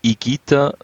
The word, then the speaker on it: Higuita: Das ist ein Verwandter von dir, der Higuita. – Nein, das ist ein Grundfalsch. – Higuita. – Higuita ist ein Grundfalsch. Er heißt Higuita, weil das H ist stumm und das G-U spricht man als G aus.